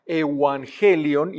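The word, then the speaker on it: evangelion (εὐαγγέλιον): Evangelio, en realidad se pronunciaba evangelion, y es el buen mensaje, las buenas noticias.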